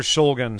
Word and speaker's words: Shulgin: Alexander Shulgin.